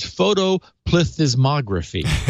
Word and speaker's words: photoplethysmography: And so the official pronunciation is photoplethysmography.